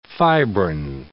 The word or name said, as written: fibrin